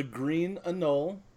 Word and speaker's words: Green Anole: And he's going to be here today to talk about his book, The Secret of the Green Anole. Did I pronounce that right? – You pronounced it perfectly.